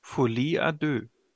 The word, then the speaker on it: Folie à deux: Wie wird denn der Titel tatsächlich korrekt ausgesprochen? Folie à deux.